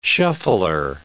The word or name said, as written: shuffler